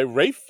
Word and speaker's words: Ralph: played by Rafe Fiennes or Ralph Fiennes, however you say it. Rafe. It's, it's pronounced Rafe.